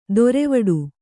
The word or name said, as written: ದೊರೆವಡು